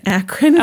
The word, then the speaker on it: Akron: How do you say it? – Akron. – Oh. Thank you.